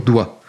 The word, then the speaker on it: doigt: À la racine de notre problème, on a le mot latin digitus, qui veut dire doigt. C'est à cause de digitus que doigt s'écrit avec un G silencieux,